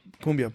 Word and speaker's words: cumbia: Cumbia, perfect.